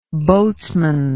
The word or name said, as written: boatsman